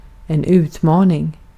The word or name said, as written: utmaning